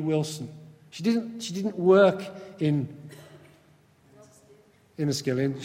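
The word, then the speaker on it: Enniskillen: Remembrance Day, 1987, the Northern Irish town of Inniskillen. Have I said that correctly? How much? See, there you go. Exactly like I said it.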